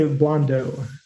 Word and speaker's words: Blondeau: If you're going to take a crack at it, is it Blondeau? – No. – Okay. – we just say Blondeau.